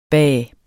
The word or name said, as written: bah